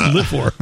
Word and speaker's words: livor: Is it pronounced liver or livor? Because it's spelled livor. And livor. – Livor, livor. – It doesn't matter. – Yeah. You're not a local. It's livor. Livor.